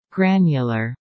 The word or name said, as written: granular